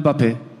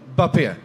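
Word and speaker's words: Mbappé: med sitt stumma M i början av allt. Eh, alltså, är det så? Kan vi, få, kan vi få ett riktigt uttal på? – Kylian Bappé. Bappé? Ja, exakt så.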